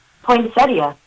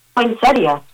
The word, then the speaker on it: poinsettia: Poinsettia. – Oh, there we go. – Poinsettia. – Poinsettia. – That's right. P-O-Y-N-S-E-T-E-U-H.